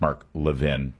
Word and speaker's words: Mark Levin: Maybe it's because they know that it's really just conservative media that are the problem, like Mark Levin. It's pronounced Mark Levin.